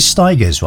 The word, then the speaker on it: Stigers: And then Curtis Stigers, rhymes with tigers.